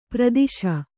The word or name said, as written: ಪರದೇಶಿ